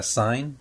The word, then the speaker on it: sign: La letra G en la palabra sign no se pronuncia.